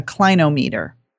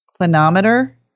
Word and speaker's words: clinometer: And um, it looks kind of cool and it's called a, hope I'm saying this right, a clinometer.